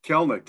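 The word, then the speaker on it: Kelenic: Kelnick.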